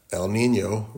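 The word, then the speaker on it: El Niño: In fact, if you want to remember that, El Nino rhymes with San Diego.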